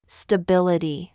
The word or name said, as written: BOB